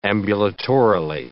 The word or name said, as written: ambulatorily